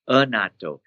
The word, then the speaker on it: annatto: The fermented soy is the a Japanese word called natto, which is N-A-T-T-O. – Yeah. – I know it sounds, this one is annatto. – Oh. – A-N-N-A-T-T-O. It just have this second and third syllable the same, but it's not from a fermented soybean.